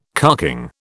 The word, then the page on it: carking: British English